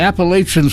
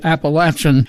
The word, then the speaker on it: Appalachian: Oh, I know, it's supposed to be Appalachian. I don't buy that. I feel that the Southerners pronounce it Appalachian, and we have always pronounced it Appalachian, so I'll stand by that.